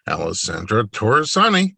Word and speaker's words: Alessandra Torresani: Alessandra Torresani, welcome to the podcast.